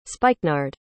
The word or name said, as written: spikenard